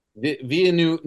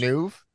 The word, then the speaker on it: Villeneuve: First, how do you pronounce the director's last name? – Villeneuve. – Is it? – It's French. – Is that real? – It's I French, say even though he's Spanish. Villeneuve.